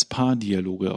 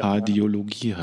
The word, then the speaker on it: Paardiologie: Als letztes Thema auf der Agenda steht das, was man immer als Paardialoge aussprechen will, was wohl ja. Paardialogie heißt.